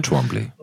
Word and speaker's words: Twombly: Und zwar beginnt unserer, unser Film beginnt ähm, mit äh, Joaquin Phoenix alias äh, Theodore Twombly, wenn ich es richtig ausspreche. Theodor Twombly. Theodor ja. – Twombly, genau.